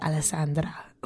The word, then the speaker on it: Alessandra: Everybody pronounces my name wrong because there's no real right way to say my name fully unless you have an Italian accent. You're like Alessandra. That's the only way.